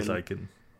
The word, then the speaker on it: Blaziken: But I don't want to slight Blizzkin or Combusken. – Blaziken. – Yeah, thank you. You know the proper pronunciation.